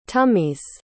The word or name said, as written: tummy's